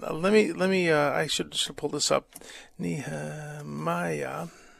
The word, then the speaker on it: Nehemiah: In Hebrew. No, it is the same. It means the Lord consoles, Nehemiah in Hebrew. But in English, it's Nehemiah. That's, as far as I know, the correct pronunciation.